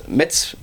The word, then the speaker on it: Metz: Et dimanche, Nice, Saint-Etienne, Monaco, Metz, Metz. – Et Lyon-Paris. – C'est comme ça qu'il faut le prononcer.